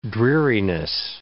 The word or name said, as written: dreariness